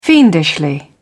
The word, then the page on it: fiendishly: British English